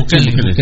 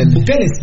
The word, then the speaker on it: Bukele: Bukele. Bukele.